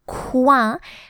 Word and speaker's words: coin: It reads just like the word coin. It's spelled like the word coin, but it reads coin.